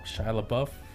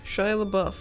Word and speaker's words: Shia LaBeouf: Shia LaBeouf. – Shia LaBeouf.